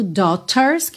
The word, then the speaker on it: daughters: Atenção à pronúncia dessa palavra, porque se escreve D-A-U-G-H-T-E-R-S. Então, às vezes parece que vai ser uma pronúncia bem diferente, mas esse A-U aí a gente vai falar como se fosse um O. Dó, dó, ok? O G-H não vai ter som nenhum.